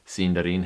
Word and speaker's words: Sindarin: In Sindarin, you put your emphasis on the third to the last syllable if your word has more than three syllables. Sindarin happens to have three syllables, so unlike what I was doing last time, putting the emphasis on the dar, you're going to put it on the sin in.